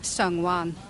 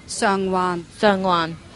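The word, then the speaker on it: Sheung Wan: However, it's not Sheng, it's Sung. – Sung. – There's no sh in Cantonese. So, why do I spell it with SH? I do not know, and it's a pet peeve uncovered.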